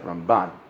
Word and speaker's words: Ramban: When you say Ramban, the accent is on the left side, Ramban.